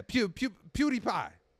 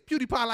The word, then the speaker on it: PewDiePie: Let me see if I'm saying this right. Hold on. PewPie. Oh, no, PewDiePie. Oh, no, PewDiePie? Yeah, PewDiePie. Oh, PewDiePie. The show is PewDiePie like Cutie Pie.